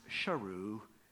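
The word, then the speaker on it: Sheru: Saru would later learn that his name is properly pronounced Sharu, which means lion.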